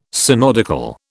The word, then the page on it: synodical: British English